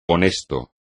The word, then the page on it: honesto: espagnol